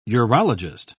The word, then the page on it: urologist: British English